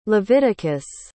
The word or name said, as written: leviticus